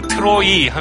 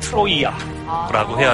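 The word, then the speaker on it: 트로이아라고 (Τροία): 그 트로이 하면 이것도 역시 영어식 발음이고 아~ 그리스어에 충실하게 발음하면은 음. – 트로이아라고 해야 한다.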